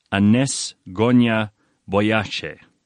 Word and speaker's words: Anjezë Gonxhe Bojaxhiu: Her name, her real name, Anjezë Gonxhe Bojaxhiu. That's how you'd pronounce it.